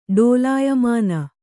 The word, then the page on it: ಡೋಲಾಯಮಾನ: Kannada